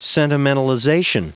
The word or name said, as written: sentimentalization